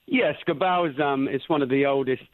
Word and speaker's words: Scabal: Now, you work with a company called Scabble, C-S-A-B-A-L. Did I pronounce that correctly? – There's a letter or two wrong. It's pronounced Scabal, S-C-A-B-A-L, Scabal.